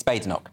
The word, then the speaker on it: Badenoch: I want Kemi Badenoch to win, or Badenoch. Right. Is it Badenoch? – It's Badenoch. – It's Badenoch. – Yeah.